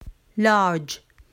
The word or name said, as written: large